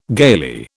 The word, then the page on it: gaily: British English